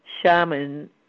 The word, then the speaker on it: Shaman: It's Natasha, N-A-T-A-S-H-A, that part's easy, Shaman, S-H-A-M-A-N, like shaman, like somebody who works magic.